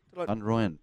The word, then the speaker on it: van Royen: Did I pronounce that correctly? – Van Royen, perfect.